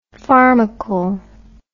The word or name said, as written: pharmacal